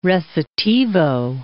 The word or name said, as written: recitativo